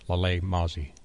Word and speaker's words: Lalae Mozie: And is your first novel also uh, released under the same name, under your name, Lalae Mozie? – Yes.